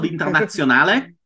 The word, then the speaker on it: Internazionale: Now, how do you pronounce it? You pronounce it the Italian way. – I pronounce it the Italian way because I grew up calling it like that, so the Internazionale.